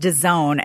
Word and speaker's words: DAZN: Apparently, it's pronounced DAZN.